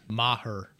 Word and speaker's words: Maher: And Maher got, you know, he had his own days. – How do you pronounce the kicker's name? – Maher. – How do you pronounce it? – Maher.